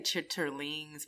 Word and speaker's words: chitterlings: Yeah, you got to say it right. You could say chitterlings. But chitterlings? – Yeah, that's the proper way to say it, but... And my family and my culture is chitlins.